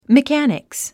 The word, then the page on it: mechanics: mikǽniks; メカニクス